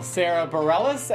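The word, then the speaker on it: Sara Bareilles: Nice little Sarah Bareilles. Am I saying this correctly? – You are.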